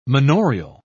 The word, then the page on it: manorial: British English